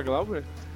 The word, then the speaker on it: Glauber: Mas eles conseguem pronunciar Glauber? – É, sim. É que você fala que nem coruja, que é Al, né? Aí fala, Glauber.